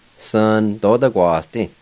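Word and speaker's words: svnadodaquasdi: And the A with the N, you can leave that silent if you want to. Most people would leave that silent. They'll say it like this. Sa-na-do-da-gua-s-di.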